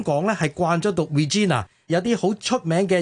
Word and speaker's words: Regina: 系啦，我惊尴尬读错咗咧，所以我就跟翻香港人所读 Regina。咁拉丁文亦都 Regina 啊嘛。不过如果大家要跟足英国人嘅传统发音咧，读 Regina 咧，Elizabeth Regina the Second。